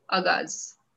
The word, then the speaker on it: Agaz: And today we have with us poet Basabha Datta Mishra, who recently launched his book of poetry, Agaz. Did I say it right? – Hello everyone. Hello. Yes, you have pronounced it right.